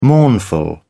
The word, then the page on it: mournful: British English